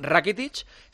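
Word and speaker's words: Rakitić: Bueno, total, esto ha dicho Rakitic, que se pronuncia así, Iván Rakitic, sobre cómo está ahora en el Barça.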